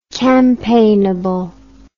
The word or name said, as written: campaignable